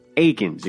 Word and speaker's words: Aikens: And yesterday, the Spartans landed a long-coveted four-star point guard for the 2021 class as Yipsy Prep Academy's Jaden Atkins gave his verbal commit to Tom Izzo and the Spartans. How cool is that? And actually, I'm saying it wrong. Atkins. Aikens, excuse me.